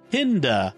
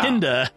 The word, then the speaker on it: Kinda: We'll be discussing the fifth Doctor story, Kinda. I said it right this time. – Yeah. – Kinda, not kinda, Kinda.